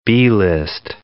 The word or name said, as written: B-list